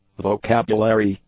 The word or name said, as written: vocabulary